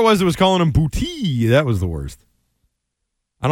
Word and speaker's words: Boute: How do you say your last name?" He goes, Booty. It's Booty, just Booty.